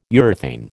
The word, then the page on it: urethane: American English